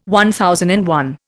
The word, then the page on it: MI: Indian